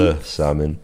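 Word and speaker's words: Le Samyn: but in the sprint behind, it was Emma Norsgaard who triumphed and then put in a great display also at Le Samin, before I get pronunciation police. – Le Samin.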